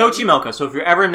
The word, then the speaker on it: Xochimilco: I can't pronounce it, it has an X in it. – Xochimilco. – Xochimilco? – It's Xochimilco.